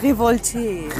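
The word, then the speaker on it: Révolté: Revoltes. das war wahrscheinlich Iri, falsch. Iri Revolte. – Ja. Das heißt locker Revolté.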